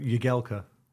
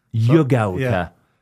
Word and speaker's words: Jagielka: Because I call you Jags, people call you Philip, Phil, Jagielka. What is it? – It's full titles, Philip Jagielka. – Jagielka. – And if I was probably saying a bit more Polish, it'd probably be Jagielka.